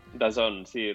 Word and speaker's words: DAZN: Sempre em costa pronunciar aquesta plataforma perquè la O no hi és, però es diu, no? – DAZN, sí.